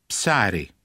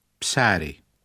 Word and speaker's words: ψάρι: Ψάρι. Ψάρι.